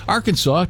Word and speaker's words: Arkansas: And then Arkansas took the top spot here. A lot of people still wondering why it's not pronounced like Kansas, like Arkansas, right?